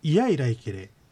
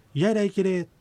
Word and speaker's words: イヤイライケレ: これはどういうことかと言いますと、ゆっくりと発音した場合にはいやいらいけれとなるわけですけども、普通の発音の場合にはこのいが抜けてしまってイヤイライケレと発音されるわけです。